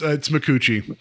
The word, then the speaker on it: Micucci: So our cast is led by Kate Minnucci? – No, it's Micucci. – Micucci, okay. Sorry. – Yeah, no, it's I looked up the actual pronunciation. It's Micucci.